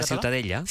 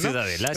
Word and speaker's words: Ciutadella: ¿cómo se pronuncia? – La Ciutadella. – Ciutadella. Bueno, pues Ciutadella también en castellano. – Ciutadella, sí.